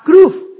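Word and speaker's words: kruf (כרוב): Los querubines, la palabra hebrea querubín es la palabra kruf. Se las voy a deletrear. Es casi imposible de pronunciar en el castellano. K-R-U-W-B.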